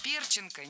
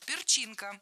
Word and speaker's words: перчинка: Перченка, неверно. Правильный вариант – перчинка с ударением на втором слоге.